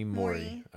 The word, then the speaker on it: Morey: It's like Morey. Like a Morey, like money. Like money. – Money Morey. Okay. – Yeah. – Sarah Morey.